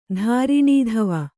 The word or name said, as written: ಧಾರಿಣೀಧವ